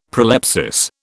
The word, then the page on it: prolepsis: British English